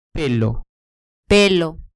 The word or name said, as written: pelo